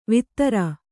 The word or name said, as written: ವೇತ್ರ